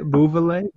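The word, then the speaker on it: Beauvillier: They weren't messing with my man Anthony Beauvillé. Is that how you say it? Beauvillé? How you say it? – I think you're right. – Is that how you say it? – Yes.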